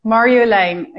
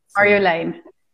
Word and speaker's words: Marjolaine: Sim, é difícil, mas é Marjolaine. É com Mar, Yo, como Yo, e Lane. Marjolaine.